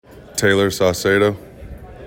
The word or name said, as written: Tayler Saucedo